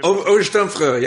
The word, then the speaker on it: Obersturmführer: Obersturmführer.